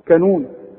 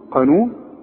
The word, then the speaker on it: قانون: وعندك بقى في الكلمات البلد بتاعتنا بقى بنت المتداولة، عمرك ما تقول على القانون كانون، تقول كانون قانون، وتقول قابوس ما تقولش ايه، هتقول كابوس قابوس قرار ها قرض. خلاص عرفنا القاف، وارجوك تاخد بالك من نطقها السليم.